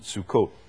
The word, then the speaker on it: Sukkot: And tabernacles, or booths, or the word is Sukkot. It's how we normally pronounce it, Sukkot.